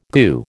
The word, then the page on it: goo: American English